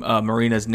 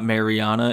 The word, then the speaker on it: Marina: I've been saying Marina's name wrong for literally every single time she's had a fight and I've said her name. It's not Mariana, it's Marina. So I'm sorry, Marina, but she loses a close split decision to Jessica Andrade.